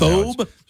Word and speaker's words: thobe: A thobe? – That rhymes with robe. Somebody in the app chat says it thobe. – Thobe. – Rhymes with robe. – Finest thobe, please. – Thobe, right? T-H-O-B-E.